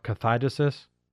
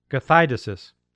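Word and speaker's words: kathidesis (καθαίρεσις): Well, if we look at the original meaning of the word pulling down, casting down, yanking down the stronghold, the word is, it's a long word, it's four syllables. It's a Greek word called kathidesis. And that word is the word that Paul used in 2 Corinthians 10:4, kathidesis.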